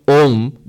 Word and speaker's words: Om: If you take it together, it's not Aum, by the way. Little knowledge dangerous. I said, oh, I didn't know Om was A, U, Ma. Now I know. So the correct pronunciation is A, U, Ma. Aum. No. With the rules of Sanskrit grammar, A and U, when you put them together, they become Om. They become O and then Ma. Mm. So Om, that's the correct pronunciation actually.